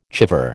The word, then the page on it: chèvre: American English